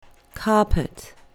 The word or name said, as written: carpet